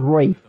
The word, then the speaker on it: Ralph: And Ralph Fiennes prefers to be called Rafe, but I'm the one talking here, so that's his tough.